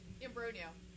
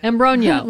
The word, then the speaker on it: Imbrogno: So we got Molly Alphabet, Chet Vincent, Trish Imbrogno. Did I say that right? – Close. Yes. – Close? What is it? – Imbrogno. – Okay. – Like Imbrogno. – Like lasagna, she says.